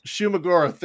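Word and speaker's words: Shuma-Gorath: The first fight where they're fighting not Shuggoth Goroth, however you say his name, Shuggoroth, whatever. – Shumagoroth, there it is.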